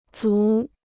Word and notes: 族: zú

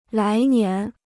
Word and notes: 来年: lái nián

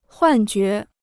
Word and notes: 幻觉: huàn jué

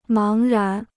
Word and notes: 茫然: máng rán

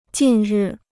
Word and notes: 近日: jìn rì

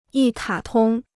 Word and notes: 一卡通: yī kǎ tōng

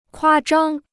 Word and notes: 夸张: kuā zhāng

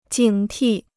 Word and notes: 警惕: jǐng tì

